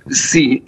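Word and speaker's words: si (四): Parce que 4, je vais mal le prononcer, mais ça se prononce si. Et si, ça veut dire aussi mort, à quelques nuances de prononciation près.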